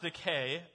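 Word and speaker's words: Dike: Now, it's pronounced decay. Uh, the, the E should be elongated there.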